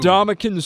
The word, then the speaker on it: Ndamukong: It's Ndamukong. – Ndamukong? – Ndamukong. – So you were wrong. That's not what you said earlier. – Well, I heard Brent Musburger on Saturday night. – Ndamukong Sue.